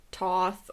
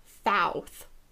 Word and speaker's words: Thoth: The ancient Egyptians believed that the god, some pronunciations differ. People say it as either Toth or Thoth. – I heard at least one of those. I've heard Thoth, I think. Yeah. Nah, that's hard to say. – Yeah, it is. When I was looking up pronunciation, the source I had was somebody that said that the safest way is pronounced Thoth.